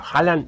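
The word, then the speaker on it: Haaland: que ahora se dice así. Holland eh, es Holland.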